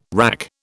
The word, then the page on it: WRAC: British English